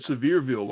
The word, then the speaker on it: Sevierville: She said Sevierville, like the word severe. And my wife is like, oh, wait, no. It's spelled S-E-V-I-E-R.